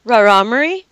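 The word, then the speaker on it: Rarámuri: proper name is it the Rahmari? How do you say the Indians' names down there? – Rahmari. – Rahmari.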